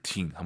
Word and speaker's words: 挺: 초급자분들이 팅 발음 들으면 보통은 듣다만 떠올려요. 挺好的 할때 팅이 듣다가 아니죠. – 아, 不是。 – 네, 성조만 다를 뿐이에요. 병음은 똑같아요. T-I-N-G.